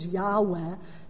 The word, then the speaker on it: Yahweh: and some people like to pronounce it as Yahweh because the first letter is Yud, which is like a Y, and the second letter is a He, which is like a H, and the third letter is Vav, which is more like a W than a V, and the last letter is another He.